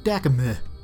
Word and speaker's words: Dakimh: They awake in mystical chains confronted by an enchanter, whose name is, and I may have this wrong, it's spelled D-A-K-I-M-H, which I believe is pronounced Dakimeh.